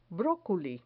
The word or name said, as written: broculi